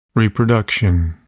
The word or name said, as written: reproduction